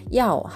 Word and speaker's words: 要: やお、発音は YAO、第 4 世、上から下に下がる音です。ヤオ、上から下に急降下するような感じで発音してみてください。